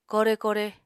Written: kore-kore

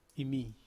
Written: mi

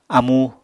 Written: amú

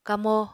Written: kamo

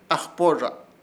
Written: AJPORRZA

